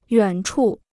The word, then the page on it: 远处: yuǎn chù